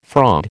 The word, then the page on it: fraud: American English